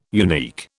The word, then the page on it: unique: British English